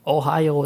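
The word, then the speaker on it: Ohio (おはよう): Ohio, that's how you say Ohio. Good morning in Japan.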